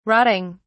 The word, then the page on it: rotting: British English